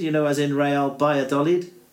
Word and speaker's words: Valladolid: Starts with a V, but it's pronounced B. Valladolid. Yeah, it, it, it looks like Valladolid, but it's not in it. – It's hmm. supposed to be Vi- Baya Dolid, I think.